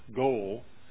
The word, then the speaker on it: goel: He has to have what in Hebrew they use the word goel. I believe that's how you pronounce it, G-O-E-L.